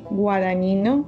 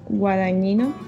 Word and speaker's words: Guadagnino: El director es Luca, perdona si no, no pronuncias tú bien, Guadagnino, Guadañino.